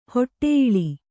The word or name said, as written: ಹೊಟ್ಟೆಯಿಳಿ